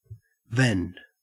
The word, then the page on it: vend: Australian English